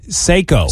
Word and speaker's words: Seiko: Seiko.